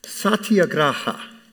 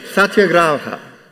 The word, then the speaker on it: Satyagraha: It's pronounce Satyagraha. It's not Spanish. I'm not speaking in tongues. Can you pronounce that? Satyagraha. Try again. Satyagraha. Right. It's actually an East Indian word.